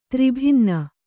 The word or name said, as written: ತ್ರಿಭಿನ್ನ